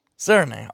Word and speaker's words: Serigné: Okay, so you know it's a French name and it looks a little bit confusing there at the end, but you pronounce it Serenay. – Serenay. Okay. – And the way to remember that is like when you serenade someone, just get rid of the D, and that's the way it sounds.